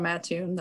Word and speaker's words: Mattoon: So I'm originally from Mattoon, Illinois, which there is debate on how you pronounce that, but if you're from Mattoon, that's how you pronounce it.